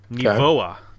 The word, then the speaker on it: Nivoa: I learned how to properly pronounce them, Jay. Nivoa is the proper pronunciation. I went to a pronunciation website for Swedish words and plug that in.